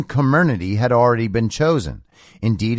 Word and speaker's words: Comirnaty: Pronounced phonetically as Comirnaty,